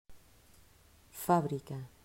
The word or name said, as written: Fabrica